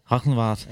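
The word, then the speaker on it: Galgenwaard: Altså, jeg har været, du har spillet flere kampe på øh, stadion Galgenwart. Rigtig udtalt? – Rachenwart.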